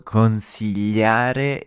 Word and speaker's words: consigliare: The word consigliare is a bit of a mouthful. – Yeah, and right in the middle we've got the L sound, which is spelt G-L-I. You place your tongue flat across the top of your mouth and glide it forward. L, L, L.